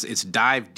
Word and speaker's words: DIIV: It's DIIV, D-I-I-V. It was called, you spell it the regular way, but it's after the Nirvana song.